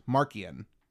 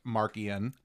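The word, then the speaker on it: Marchion: And as I learned from watching the High Republic show, Markian Rowe, it's not Marcion, you know, it's not a C-H like a ch, it's like a K sound, Markian.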